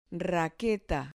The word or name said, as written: raqueta